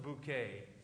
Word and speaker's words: Bouquet: One of my favorite TV shows is an old British sitcom, and it's about Hyacinth Bouquet. I don't know if any of you watch Hyacinth on TV. Her last name is spelled B-U-C-K-E-T, And she gets extremely agitated when people call her Hyacinth Bucket.